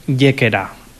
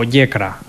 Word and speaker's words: Yécera: O Yecra, también se puede pronunciar a veces, Yécera o Yecra, de las dos maneras. Nunca digáis Yéqueda con D, porque eso es un pueblo de Huesca, ¿vale? Ni Yecla con L, que es un pueblo de Murcia.